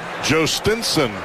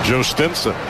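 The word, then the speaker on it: Jhostynxon: Jostinson Garcia. Not that that's how it's spelled, but that's how it's pronounced.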